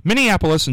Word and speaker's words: Minneapolis: Minneapolis and St. Paul, sister cities, but I'm not sure why they are sister cities.